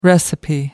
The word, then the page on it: recipe: American English